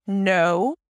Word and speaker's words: know: Listen, know. So obviously there is the K that is not pronounced. It starts with an N sound, then the O as in go.